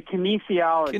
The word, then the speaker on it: kinesiology: Say kinesiology.